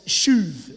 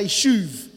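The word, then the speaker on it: shuv (שׁוּב): And so he restores in Hebrew is pronounced as shuv. Everyone say shuv.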